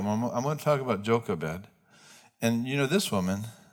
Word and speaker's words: Jochebed: It's not a joke, but the emphasis is Jochebed. That's how you pronounce it.